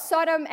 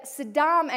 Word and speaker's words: Sodom: And I remember one day asking a question about Sodom and Gomorrah, and I mispronounced it. And I said, Saddam and Gomorrah.